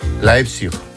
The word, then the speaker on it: Leipzig: Leipzig.